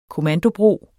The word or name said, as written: KOMMANDOBROER